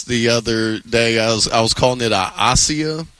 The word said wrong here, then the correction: acai: Acai. – Oh, yeah, okay. – Yeah, everybody in L.A. knows how to pronounce acai. – Yeah, in Cleveland, not so much. – A-C-A-I.